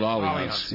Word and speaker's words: Allianz: It's spelled like Alliance, but for whatever reason, they call it Allianz.